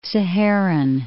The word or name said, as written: Saharan